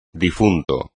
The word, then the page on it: difunto: espagnol